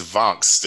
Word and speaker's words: Vangst: Yeah, so correct pronunciation is Vangst. It's Dutch for catch. Dutch for to catch.